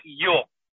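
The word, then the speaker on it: Йо (יה): Если написано буква Юд и Гей, это читается как Йо, да, или Я.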